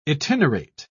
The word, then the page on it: itinerate: British English